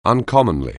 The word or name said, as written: uncommonly